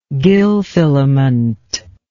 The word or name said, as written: gill filament